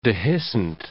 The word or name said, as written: dehiscent